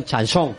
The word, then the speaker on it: chanson: Atención a mi pronunciación, chanson.